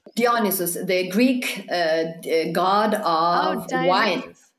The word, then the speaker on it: Dionysus: In English, we would pronounce Dionysus.